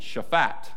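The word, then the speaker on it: Shaphat (שפט): You got to put the accent on the end. Shaphat, which means to judge, govern, vindicate, or punish.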